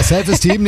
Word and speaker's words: Self-Esteem: The Offspring, Save Eastern. – Ja, die Aussprache ist ein bisschen... – Steam, hier ist Steam. – Ja. Ja, ja, ist richtig. – Ja, Self-Esteem nehmen wir auch.